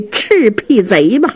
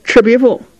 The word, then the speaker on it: 赤壁赋: 严茶伞自己写的，写的一首《赤壁赋》。哼，冯君衡一瞧，你这赤壁贼写的不坏呀，来来来来，劳你大驾，我这有把扇子，这面是素的，你也给我写上这赤壁贼吧。雨墨不敢公开的顶了，小声嘀咕，连字儿都不认识，赤壁赋念成赤壁贼了，真不是东西。嗯，真不是东西这句话呀，声音稍微大这么一点儿，这冯君衡好像听见了，一歪头，严茶伞呢就给岔和过去了。